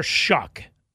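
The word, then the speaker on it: Shough: It's spelled so weird, yeah, but I've heard Shuck, everyone. Right? Yeah, I've heard everyone say Shuck. – That's what I've heard too, but it is spelled, to your point, S-O-U-G-H. – Right, yeah. But it's Tyler Shuck. That's how he pronounces it.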